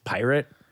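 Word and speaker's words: PYRT: Billy McFarland is following up the Fyre Festival with it's P-Y-R-T, all caps again. So it's not part. He says it's pronounced pirate. Ugh. Which, that, even with liberties, that ain't how you spell pirate.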